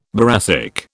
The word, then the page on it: boracic: British English